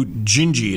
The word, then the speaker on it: Dindi: If not, you can do Gingy. It's how it's pronounced. It's spelled D I N D I just Dindi. It's how it's spelled, but it's pronounced Gingy in Brazilian.